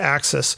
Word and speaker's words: AXS: That's AXS. But it's being pronounced Axis.